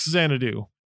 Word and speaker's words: Xanadu: All right, so now let's bring in Van Fertel, who also has played Fact Xanadu. And we're also going to say from the get-go that that's how we're going to pronounce it, because that's how it sounded on the show Captain N.